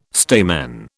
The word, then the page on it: stamen: British English